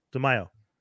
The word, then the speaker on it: DeMaio: Actually, the news from that, Bo DeMaio, is that how you pronounce it? DeMaio? – Yeah. – DeMaio. – Yeah, I would assume.